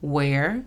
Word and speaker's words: where: Where significa dónde. La pronunciación fonética es U, E, R. Where.